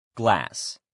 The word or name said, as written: glass